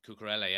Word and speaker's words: Cucurella: They brought this, how do you say, Cucurella, is it? – Cucurella, yeah.